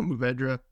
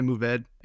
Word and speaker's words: Mourvèdre: It's Syrah, Grenache, and Mourvèdre. Help me on that last one. – Yeah, no, Mourvèdre. Some people say Mourvèdre. If you get real French about it, it's like Mourvèdre.